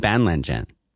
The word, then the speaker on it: Banlangen: That is how I pronounce the Chinese. It is spelled Banlangen.